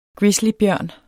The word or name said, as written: ursus